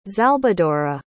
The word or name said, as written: xalbadora